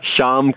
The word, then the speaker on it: Shyam: It's silent. Why is silent? – What rubbish. Okay, please. It's Shyam. Shyam. Like Ryam.